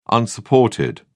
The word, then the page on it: unsupported: British English